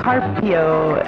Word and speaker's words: Carpio: Like loose, like light, L-U-Z. Mila Carpio is how it's spelled. Car. Pio, pio, like the bird.